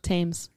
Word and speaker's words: Thames: Thames. So, what is it? – Thames. H is silent.